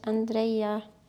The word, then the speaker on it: Andreia: Mein Name ist sehr integriert, ja. aber du kannst es ja richtig aussprechen. Andrea.